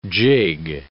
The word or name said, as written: jig